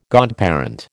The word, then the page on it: godparent: American English